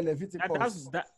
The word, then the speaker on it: Leviticus: Yeah, Leviticus.